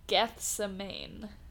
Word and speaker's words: Gethsemane: Oh, Gethsemane. – Okay. – Gethsemane. – Just pretend you didn't hear that. – Yeah. – Tune in next time for episode 424, Gethsemane. Gethsemane. – Gethsemane.